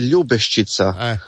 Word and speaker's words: Ljubeščica: Ti si rekao ljubeščica i onda ti se Tom ojavio i rekao ti da ti je naglasak krivi i poslao nam je, ja sam ga zamolila da snimi, da čujemo izgovor, da znamo za ubuduće. – Izgovara se ljubeščica.